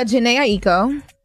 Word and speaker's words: Jhené Aiko: So, Jhené Aiko.